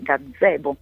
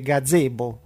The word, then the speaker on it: gazebo: In italiano, peraltro, non so se l'hai notato, ma noi diciamo gazebo, si scrive con una Z sola, però quando lo pronunciamo lo pronunciamo con due, gazebo. – È vero, sì, se no suonerebbe gazebo, che magari è un'altra cosa, non lo so, però effettivamente la pronuncia a due Z, giusto?